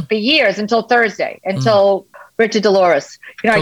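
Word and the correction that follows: DesLauriers: DeLaurier, I can never say his name. – DeLaurier.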